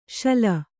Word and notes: ಸೂಲೆ: Kannada

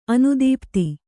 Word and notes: ಅನುದೀಪ್ತ: anudīpta; kannada

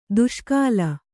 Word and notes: ದುಷ್ಕಾಲ: duṣkāla

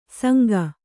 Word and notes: ಸಂಗ: sanga; Kannada

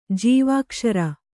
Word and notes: ಜೀವಾಕ್ಷರ: Kannada; jīvākṣara

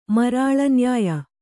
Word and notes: ಮರಾಳನ್ಯಾಯ: marāḷa nyāya

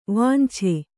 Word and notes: ವಾಂಛೆ: vānche

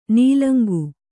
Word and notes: ನೀಲಂಗು: kannada; nīlaŋgu

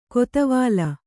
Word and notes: ಕೊತ್ವಾಲ್: Kannada

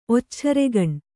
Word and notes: ಒಚ್ಚೇರೆಗಣ್: Kannada

